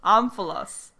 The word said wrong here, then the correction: omphalos: But I think it's Omphalos. – Omphalos. – Omphalos. – Yeah. – Well, of course you knew. – I didn't know the word. I just looked at how to pronounce it. – All right. It's called Omphalos.